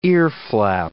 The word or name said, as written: earflap